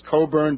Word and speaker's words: Cockburn: I think it's pronounced Coburn, but written Cockburn.